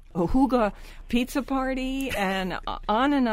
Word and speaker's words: hygge: And don't forget, people, it's spelled, The Higgy Life is the name of the book, and it's spelled H-Y-G-G-E, but you pronounce it in the language Huga. You know, H-O-O-G-U-H, Huga, like that.